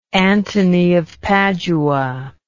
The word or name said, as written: Anthony of Padua